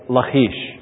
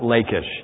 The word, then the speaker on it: Lachish: Lachish. Now, I know that's not the way you probably pronounce it. You may pronounce it Lachish.